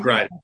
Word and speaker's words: Grinder: I think the thread of the interview today is going to be about the song that you wrote with Josh Grinder, and how did that come about? Is that how he pronounces his name? I didn't. – Grinder, Josh Grinder. Yes, sir.